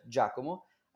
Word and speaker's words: Giacomo: It's just Giacomo, and the first the first uh, sound is kind of tricky. The G is like uh, phonetically is D plus J, J.